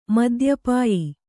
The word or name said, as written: ಮದ್ಯಪಾಯಿ